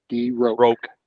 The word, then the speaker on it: de Roque: You pronounce it R-O-K-E. DeRook. – DeRook. – It's just a K. It's a Q-U-E on the end is French, and you pronounce it as just like you would a K.